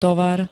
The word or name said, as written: tovar